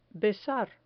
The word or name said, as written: besar